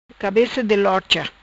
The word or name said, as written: Cabeca di lorcha